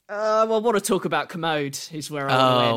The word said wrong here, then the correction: Kermode: But, sorry, Kermode. I always say it wrong. – Not Commode, as in the other word for a toilet.